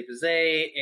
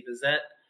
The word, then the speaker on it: Bizet: And for Bizet, and I think we mentioned this a little while ago, but the pronunciation of his last name, it mm-hmm. depends on a sort of regionality. Apparently, like Americans sometimes say Bizet, and the British sometimes say Bizet.